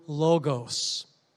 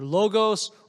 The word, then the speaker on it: logos: In the original Greek language, Word is the word logos, often pronounced either logos or logos. Either pronunciation will do. L-O-G-O-S.